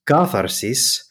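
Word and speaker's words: katharsis (κάθαρσις): And the Greek pronunciation of that word is katharsis.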